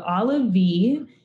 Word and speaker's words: Olivey: Okay, so Olivey, it's spelled olive with an extra E. So it's like a hard E sound.